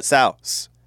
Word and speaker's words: souse: Female pig is a sow, pronounced like sauce, but souse.